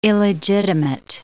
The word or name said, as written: illegitimate